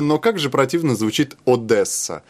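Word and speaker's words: Одеса: Дело в том, что по-русски говорят Одесса. Ну, вот русское произношение. А по-украински Одесса.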